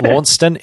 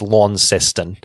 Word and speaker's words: Launceston: And so, obviously, the town in Tasmania is named after the town in Cornwall, but we fucked up the pronunciation that bad and added an extra syllable So, yeah, instead of Launceston, it's Launceston.